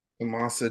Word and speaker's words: Tommaso: Um, Tomoso. Tommaso Ciampa. Thank you.